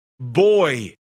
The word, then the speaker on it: Buoy: From the Seattle Kraken, Boy. And it's spelled B-U-O-Y. Get it?